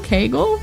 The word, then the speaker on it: Keigel: K-E-I-G-E-L. – Hold on. K-E-I-G-E-L? – Yeah, am I saying it wrong? Kegel? – K-E-I-G-E-L. – Yeah. – Dude, oh, okay, Kegel exercises. – Kegel? What? – K-E-G-E-L. – Okay, so then how would I pronounce this? K-E-I-G-E-L.